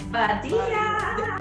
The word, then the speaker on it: Badia: Y'all correct me if I'm wrong. B-A-D-I-A, I'm just gonna spell it today. – Badia. – Thank you, that's how you pronounce it again? – Badia.